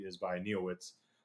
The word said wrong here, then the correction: Neowiz: Neowiz. God, I'm just all over the place with pronunciation, guys.